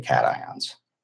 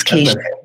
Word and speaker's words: cations: We should be seeing a downward trend in pH, not drastically, but slightly, because the plant's taking up predominantly cations. Most of the nutrients we feed it are negatively charged. – Thank you for correcting my pronunciation. I thought it was cations.